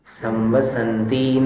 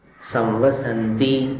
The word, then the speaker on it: संवसन्ति: अब जैसे 9वें काव्य का जो उच्चारण था उसमें एक स्थान पर थोड़ा सा स्खलन हो रहा है, संवसंति, इसमें पढ़ते समय संवसंति नगे नगे, ये गलत है। संवसंति, ये ती जो है इसको दीर्घ नहीं पढ़ना है, इसको कैसे पढ़ना है? संवसंति नगे नगे, सन, ये सन को लंबा खींच लो, ती को लंबा खींचोगे तो फिर दीर्घ हो जाएगा, तो अशुद्ध उच्चारण होगा।